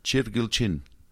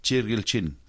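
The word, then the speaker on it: Chirgilchin: Chilquilchin. – Okay. – Mm-hmm. – All right. – Some people say Chilquilchin. It's Chilquilchin.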